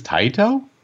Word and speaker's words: Taito: Square Enix is, I used to always pronounce this company as Taito. I guess it's Taito? – Yeah. – Taito. In my head, I've always said Taito my whole life.